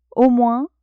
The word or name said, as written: au moins